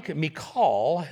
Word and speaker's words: Michal: But Michal, that's how it's pronounced in the Hebrew, David's wife, warned him, if you don't run for your life tonight, tomorrow you will be killed.